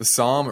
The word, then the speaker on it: Sahm: Would you say it's SOM? S-A-H-M? – It is SOM, yes. – SOM, there we go. The SOM rule, not the indicator, SOM rule.